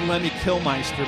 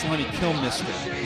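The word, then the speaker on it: Lemmy Kilmister: I always said Lemmy Killmeister, but I guess it's Lemmy Killmister is how it's pronounced.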